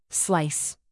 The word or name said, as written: slice